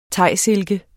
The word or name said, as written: THAISILKEN